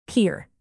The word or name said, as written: peer